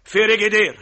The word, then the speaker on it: فريجيدير: اسمه فريجيدير يا اخي. – خلاص يا ستي، يا ستي خلصينا خلاص. فريجيدير.